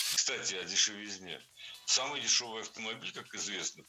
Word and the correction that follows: дешевизне: Кстати, о дешевизне прозвучало не кстати. Ведь в слове «дешевизна» ударение всегда падает на третий слог. Поэтому должно быть «кстати о дешевизне».